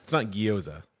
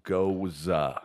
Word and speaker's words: Gose: And it's not gyoza, like the not gyoza. Like, there's no Y in there, but it's spelled G-O-S-E. But it's pronounced Goza.